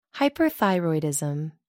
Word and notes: hyperthyroidism: American English

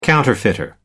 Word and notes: counterfeiter: American English

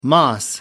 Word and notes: moss: American English

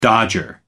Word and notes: dodger: American English

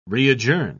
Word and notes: readjourn: British English

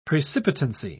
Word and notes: precipitancy: British English

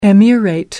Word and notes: emirate: American English

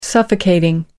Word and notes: suffocating: American English

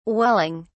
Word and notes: welling: British English

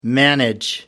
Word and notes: manage: American English

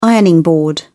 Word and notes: ironing board: British English

